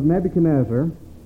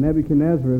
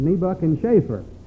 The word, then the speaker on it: Nebuchadnezzar: I heard about a fellow once who was reading through the book of Daniel, and he came across this long name, and he pronounced it Nebuch and Schaefer.